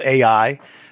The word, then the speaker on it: AEye: We have a company called AI, which I thought was one of the coolest little tricks because it's spelled A-E-Y-E. So we were actually able to make everyone say AI or artificial intelligence every time they look up our company.